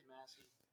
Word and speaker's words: Massey: There's Macy and there's Ferguson. – Oh. Well, there is Massey. – Is that how you say it, is Massey? I've been saying it wrong forever.